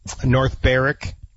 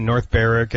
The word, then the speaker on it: North Berwick: You can play, North Berwick, which is spelled North Berwick. So there you go again, North Berwick and Gullen.